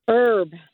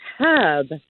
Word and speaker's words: herb: Herb. It drives me crazy. It has an H and it's herb, but everybody says herb.